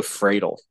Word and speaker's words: Friedel: I'm going to go Friedel because it rhymes with dreidel.